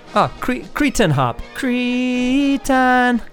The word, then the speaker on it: Cretins: Anyway, before that we heard from, I pronounce them the Cretans. How do you say it? – Cretan. – Cretan, stupid, dumb it means.